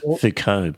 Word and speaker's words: Foucault: Well pronounced, Foucault, by the way, because we're going to be talking about French and German philosophers.